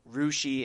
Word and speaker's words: Russia: In case anyone doesn't know, Rushi is the town that's spelled Russia.